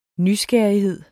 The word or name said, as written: nysgerrighed